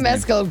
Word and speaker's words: Mescal: How do you say it? Mescal, but it makes no difference.